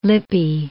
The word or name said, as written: Lippi